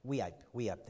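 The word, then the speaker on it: Weippe: And you pray for Pastor Mike Widener in Wipe or Weepy, Idaho. How do you pronounce that? Does somebody know? Wipe, thank you. Wipe, Idaho.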